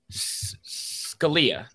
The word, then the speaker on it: Scalia: Scalia.